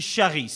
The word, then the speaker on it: charis: Et la grâce est la traduction du mot grec qui dit charis. Ça s'écrit C-H-A-R-I-S. Charis ou charis, avec l'accent, qui signifie bienveillance, miséricorde, bonté, faveur.